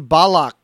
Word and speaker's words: Balak: Balak is the way we pronounce it in Hebrew, B-A-L-A-K.